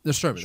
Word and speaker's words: Nostromo: Nostromo, that's it.